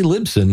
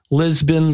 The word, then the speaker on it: Libsyn: That would be Libsyn, short for Liberated Syndication. So I'm glad you said that because I was saying it wrong the whole time we were talking. – Oh, yeah. And Lisbon, Libsyn, Libsync. I've heard them all.